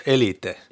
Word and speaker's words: Elite: Det är viktigt att notera, det ska uttalas Elite. – Ja. – Inte man, elit.